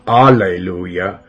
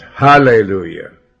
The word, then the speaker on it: hallelujah: Not alleluia, but hallelujah. Let's say it right. The H stands for God.